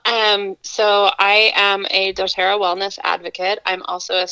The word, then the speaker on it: doTERRA: doTERRA? – Yes. Okay. doTERRA products have um, enhanced your life, I guess, and, and been something that's been really beneficial for you.